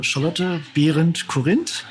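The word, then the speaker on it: Charlotte Berend-Corinth: Also Charlotte Behrendt Corinth, richtig ausgesprochen, eine berühmte Lithografie-Serie, 8 Lithografien, wo sie splitterfasernackt abgebildet wird.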